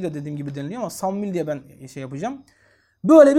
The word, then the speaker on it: Samuil: Samuil. Ya da başka bir okunuşta Eşmuil. Yani Samuil diye de okunuyor. Eşmuil diye de okunuyor.